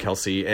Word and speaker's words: Qellessi: Instagram is it's pronounced Kelsey, but it's spelled... It's the at, and then Q-E-L-L-E-S-S-I, and Etsy is the same shop name.